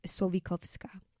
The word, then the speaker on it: Słowikowska: So it's actually Słowikowska. Mm-hmm. Anyways, we can stop talking about this. – I didn't pronounce it that way.